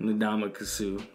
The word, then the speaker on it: Ndamukong Suh: Ndamukong Suh.